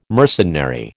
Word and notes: mercenary: American English

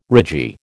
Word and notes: ragee: American English

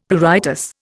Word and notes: pruritus: Indian

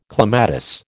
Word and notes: clematis: American English

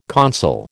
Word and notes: consul: American English

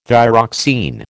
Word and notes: thyroxine: American English

